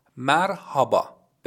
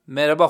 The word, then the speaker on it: مرحبا: مرحبا به معنی سلام هست. اما بچه ها اینو بهتون بگم، توی تلفظ عامیانه مرحبا نمیگن، نوشته میشه اما مربا خونده میشه یا به نوعی مربا گفته میشه.